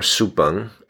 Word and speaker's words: Su Beng: The Taiwanese independence activist and failed political assassin, Shi Ming, or Su Beng, as he preferred to have his name pronounced in Taiwanese, highlighted this detail to scoff at the identification of Yizhou as Taiwan.